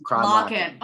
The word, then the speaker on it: Kronlokken: Kronlokken.